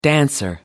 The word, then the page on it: dancer: American English